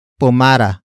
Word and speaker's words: pumara: In this context, the na particle introduces what the driver should do, and that is pumara. Pumara. Pumara. Pumara is spelled as P-U-M-A-R-A.